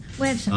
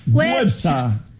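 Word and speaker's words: Güepsa: ¿Cómo se dice a los que nacieron en Huespa? Perdón, Huepsa. Websa. ¿Ah? Con diéresis. – Websa.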